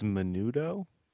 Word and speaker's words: menudo: Can someone pronounce this word before I butcher it? – Menudo. – Say it again. – I believe it's menudo.